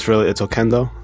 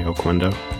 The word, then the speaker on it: Oquendo: I kind of interchange. It's Oquendo. That's how you say it in Spanish. But over the years, I've just kind of got accustomed to saying it the American way, Oquendo.